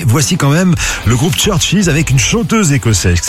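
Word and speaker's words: CHVRCHES: Écoutez, c'est Roses Churches sur RTL2. Churches, ça s'écrit avec des V à la place des U.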